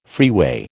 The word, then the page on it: freeway: American English